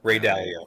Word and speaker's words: Dalio: Ray Dalio.